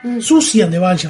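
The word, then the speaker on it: Siouxsie and the Banshees: Susian the Banshees, en realidad se pronuncia.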